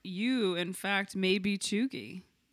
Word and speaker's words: cheugy: Have you heard the term, I don't know if I'm pronouncing it right, chuggy? – I don't know. Describe it. – It's, well, first of all, it's spelt C-H-E-U-G-Y. – C-H? – E-U-G-Y. Chuggy.